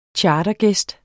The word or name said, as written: chartergæst